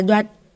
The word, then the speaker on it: Duật: Đều không phải, chữ này đọc là Duật. Thanh Bốn, nhớ kỹ chưa?